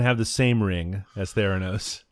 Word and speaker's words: Theranos: how do you pronounce the name of this company? – Theranos.